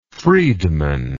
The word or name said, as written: Friedmanism